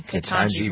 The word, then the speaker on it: Ketanji: Just because he's black, they're comparing what he's done to what this lady, Ketanji, is that how you pronounce her name? – Ketanji Brown Jackson, yeah.